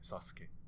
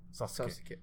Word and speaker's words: Sasuke: But in Japanese, it's a little more like subtle. It's like Sasuke. Sasuke. – Sasuke. – But I think a lot of Japanese words have emphasis on the first syllable, so it'd be Sasuke instead of for example, before I knew this stuff about Japanese, I used to think it was Suzuki.